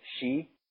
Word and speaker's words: Ski: And which is the town, Ski, Norway, but they were calling it She, like it's spelled SKI, but just their pronunciation is a little different.